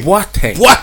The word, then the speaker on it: Boateng: Boateng. – Say it right. The actual pronunciation is Boateng. That's the actual pronunciation, if you want to get it correctly.